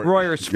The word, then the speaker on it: Royersford: Royersford. Y'all been saying it wrong and people got mad.